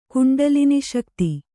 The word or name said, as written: ಕುಂಡಲಿನಿಶಕ್ತಿ